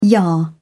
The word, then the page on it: yah: British English